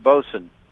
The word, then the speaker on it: boatswain: Well, it's spelled boatswain. It's pronounced bosun. – Oh, bosun. – Bosun. Yeah, you got to slur everything.